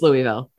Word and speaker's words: Louisville: Oh, there's a way you're supposed to pronounce it from the South, and that's not me. – Sorry, no, it's Louisville.